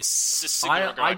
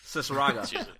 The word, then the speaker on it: Cicierega: How do you know how to say his name, by the way? Because every time I look at it, I just go, Neil. – I just say Cicierega. – Cicierega. I just trust that I'm saying it right.